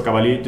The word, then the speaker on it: Cavalie: Um, on Twitter, Twitter, I'm Enzo Cavalie, just my, like my name.